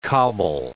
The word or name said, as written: cobble